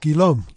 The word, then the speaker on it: Guillaume: It's G-U-I-L-L-A-U-M-E. – Pronounced Guillaume.